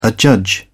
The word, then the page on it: adjudge: British English